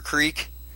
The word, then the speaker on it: creek: Right, it's like Crick or Creek.